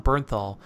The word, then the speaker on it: Bernthal: Yeah, it actually stars Tom Holland, John Berthenow, and Richard Armitage. I think that's how you say his name. Thorin from The Hobbit. – No, so it's John, John Bernthal. Bernthal.